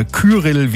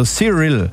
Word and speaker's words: Cyril: Cyril oder Kyrill wie der Sturm, aber es spricht sich wie Cyr, also Cyril.